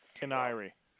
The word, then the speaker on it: Kiniry: Kiniry.